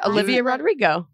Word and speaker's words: Olivia Rodrigo: Olivia Rodrigo.